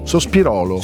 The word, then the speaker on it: Sospirolo: Sospirolo.